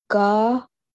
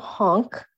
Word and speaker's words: Gahonk: Gahonk. It's not honk. Gahonk. Gahonk.